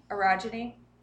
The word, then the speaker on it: orogeny: Um, I have, I pronounce orogeny, orogeny. For those of you who have read the books, you'll understand why that matters.